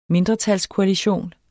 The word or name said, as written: mindretalskoalition